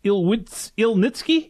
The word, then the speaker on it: Ilnyckyj: Alex Ilnitsky, is that correct? – Perfect.